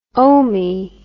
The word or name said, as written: omee